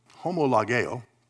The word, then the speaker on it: homologeo: That with the accent above it is the short E, and then what looks like their W is our long O sound, homologeo.